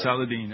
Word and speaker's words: Saladin: And I said Saladin.